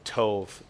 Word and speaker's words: tov: That word kalos aligns with the Hebrew word tov, T-O-V. It's spelled T-O-B, but it's pronounced T-O-V-E.